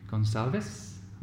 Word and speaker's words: Gonsalves: Okay, well, today I'm uh, very happy to have with me uh, Christine Gonsalves.